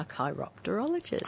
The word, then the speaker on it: chiropterologist: A chiropterologist.